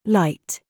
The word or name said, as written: PRICE